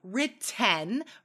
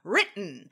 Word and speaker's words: written: We usually don't say written, right? We use that glottal stop. Written, had written, has written.